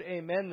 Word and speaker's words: amen: Now, it's interesting that the word amen there in the Greek text, amen is how you say it in the Greek.